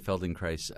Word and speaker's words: Feldenkrais: Am I even saying that correctly? – Yeah, Moshe Feldenkrais.